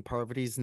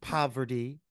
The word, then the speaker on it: Parvati: I am so fucking obsessed with Phaedra getting her lick back in the form of mispronouncing Parvati's name any chance she gets. – Parvati. – She says poverty.